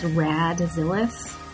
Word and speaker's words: Radzillis: I'm on Twitter and Instagram as Radzillis.